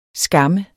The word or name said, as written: skamme